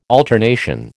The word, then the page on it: alternation: American English